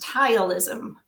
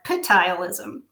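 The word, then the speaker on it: ptyalism: In this case, the woman had a condition which is spelled with a P, but it's apparently pronounced tileism. When I say it, I call it petileism.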